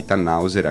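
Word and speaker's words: Tannhäuser: Approfondisci la questione fantascientifica e non solo, utopica e onirica, anche la nuovissima trasmissione Alle Porte di Tannhauser, anche se sicuramente lo sto pronunciando male. Comunque tante nuove puntate Ha detto bene.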